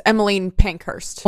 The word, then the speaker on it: Emmeline Pankhurst: So in 1911 to 1913, Smith was closely involved with the English suffragette movement led by, how would you pronounce that name? – I'm fairly certain it's Emmeline Pankhurst.